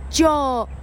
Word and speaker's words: John: 它不是念作 John John，那不是这样念，是念作 John John。当你念 John，你的鼻音已经开始走了，因为这个字拼成 J O H N，字尾的 N 它必须发出来，它在前面的母音那个部分，它就开始走了，啊，它的音程就开始动作了，它的字这个字听起来就会非常的漂亮，叫做。